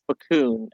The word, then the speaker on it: Facun: Yeah, it's Jeff Facun, F-A-C-U-N.